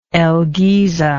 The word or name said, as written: el giza